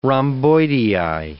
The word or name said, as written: rhomboidei